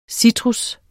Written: citrus

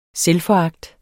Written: selvforagt

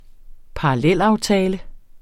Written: parallelaftale

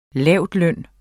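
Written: LAVLØN

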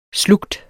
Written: slugt

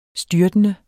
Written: styrtende

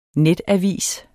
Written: netavis

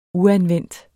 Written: uanvendt

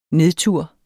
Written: nedtur

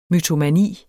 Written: mytomani

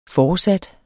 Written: foresat

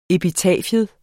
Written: epitafium

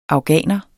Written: afghaner

